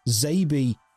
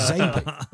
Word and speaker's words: XABY: So I traveled through the other section, and at the end I found a manual where it said Zabie is phonetic. It's related to the SNES controller. You have to press X-A-B-Y, which will be pronounced Zabie.